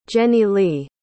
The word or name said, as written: jenilee